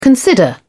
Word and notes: consider: British English

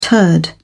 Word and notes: turd: British English

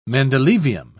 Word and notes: mendelevium: British English

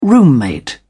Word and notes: roommate: British English

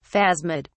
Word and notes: phasmid: British English

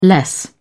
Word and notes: less: British English

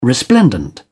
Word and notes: resplendent: British English

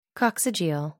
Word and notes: coccygeal: American English

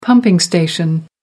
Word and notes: pumping station: American English

